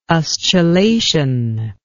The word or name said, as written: ustulation